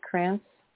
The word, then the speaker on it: Krantz: Krantz? – Yeah, yeah.